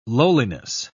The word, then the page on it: lowliness: British English